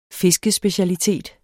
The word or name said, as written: fiskespecialitet